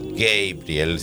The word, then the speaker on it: Gabriel: Don't Give Up, No Te Rindas, tema de Peter Gabriel, o Gabriel, si les gusta la pronunciación original.